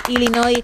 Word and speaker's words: Illinois: Así que, sin pronunciar la S final, Illinois, una vez más, tu apunte de geografía, que nos viene muy bien, sí.